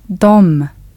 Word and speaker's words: de: In Swedish, the word for they is spelt de, but is pronounced dom.